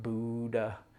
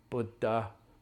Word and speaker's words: Buddha: Which, by the way, for all of you listeners, it's pronounced Buddha, not Buddha. It's not a oo, it's a It's the same U as in push or bush. Buddha, Buddha, not Buddha.